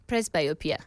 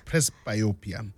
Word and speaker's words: Presbyopia: Presbyopia. – Presbyopia.